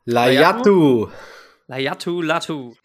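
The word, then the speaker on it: Laiatu Latu: Layatu Latu.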